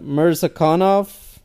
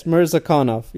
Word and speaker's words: Mirzakhanov: Oh my God, I always mess it up. Mirzakhanov. Did I say it correctly? – I think so. – Oh my God, I always feel bad. Yes, Mirzakhanov.